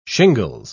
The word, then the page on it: shingles: British English